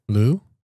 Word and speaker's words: Liu: But the author, sorry again, folks, Louis, I don't even know how to say this. L-I-U? – Yeah. – How do you say that? – Lee? – Lou? – Lou. – Works.